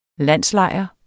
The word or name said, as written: landslejr